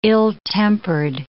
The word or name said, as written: ill-tempered